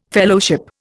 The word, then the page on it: Fellowship: Indian